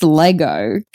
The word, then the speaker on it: Lego: So Trent and Josh, the young guys from South Australia, they mentioned that in South Australia, it's pronounced Lego, not Lego.